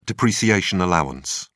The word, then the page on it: depreciation allowance: British English